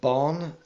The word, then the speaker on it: Bán: Pangor Bán, spelled B-A Father N and pronounced Bon as in Don, is the white cat that you see on the bottle, and he's the master of his art, or the happy hunter.